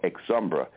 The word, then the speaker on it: Exumbra: But the magazine was called Exumbra, spelled E-X, one word part, and then U-M-B-R-A, E-X-U-M-B-R-A.